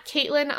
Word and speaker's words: Caitlin: I am at Caitlin IR Foster, and Caitlin is spelled C-A-I-T-L-I-N.